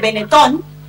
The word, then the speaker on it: Benetton: Enfrente a la Fontana di Trevi hay una tienda de ropa que se llama Benetton, que en italiano se dice Benetton, que tiene dos plantas.